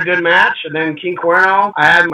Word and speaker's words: King Cuerno: And now your first dark match was King Cuero. What was it like wrestling him, if I said his name right? – King Cuerno, man. King Cuerno.